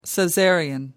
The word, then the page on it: caesarean: American English